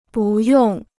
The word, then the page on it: 不用: bù yòng